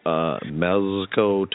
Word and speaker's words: Mezco: First of all, Mezco Toys.